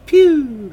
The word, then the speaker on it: Pugh: You say it like, pew!